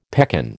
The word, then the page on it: pekan: American English